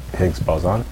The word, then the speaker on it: Higgs boson: Is that that's like the bleeding edge of scientific research right now, looking for the Higgs boson? – Yeah.